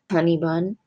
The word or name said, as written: honeybun